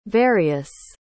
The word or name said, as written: varius